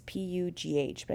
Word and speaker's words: Pugh: It's P U G H, but I think it's Pugh.